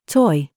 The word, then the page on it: CHOICE: British English